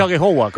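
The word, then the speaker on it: Aghehowa: Sí, yo sé que el apellido es un poco No sé si lo he pronunciado bien, pero he dicho Agyegbuwa, ¿cómo se pronuncia? – Sí, bien, la verdad que muy bien, así, muy bien.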